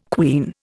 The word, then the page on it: quean: Indian